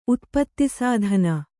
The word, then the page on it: ಉತ್ಪತ್ತಿಸ್ಥಾನ: utpattisthāna